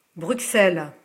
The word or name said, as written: Bruxelles